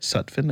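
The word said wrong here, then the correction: Sufjan: Am I saying that right? – Oh, Sufyan.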